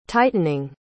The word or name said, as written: tightening